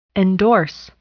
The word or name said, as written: endorse